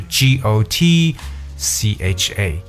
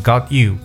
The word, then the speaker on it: gotcha: 它就是 G O T got，OK，本来是 I got you，但是呢 I got you 就变成 gotcha，哎，这个 cha 就是 C H A 这样来写，所以它合到一块呢就是 G O T C H A，但我们读的时候读 gotcha，OK，不要读成 got you，乖乖的 gotcha，哎，那其实 gotcha 的意思相当于说 I see，哎，I understand，我明白了，OK，gotcha。